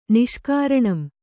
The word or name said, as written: ನಿಷ್ಕಾರಣಂ